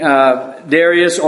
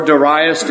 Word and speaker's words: Darius: And you know here is his being set up over the kingdom by King Darius or Darius, depending on who you hear pronounce the name. I think those are the two pronunciations of the name that you hear most often.